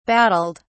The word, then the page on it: battled: British English